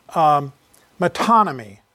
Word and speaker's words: metonymy: Well, you remember a few weeks ago that I mentioned to you a term, the term metonymy, spelled M-E-T-O-N-Y-M-Y.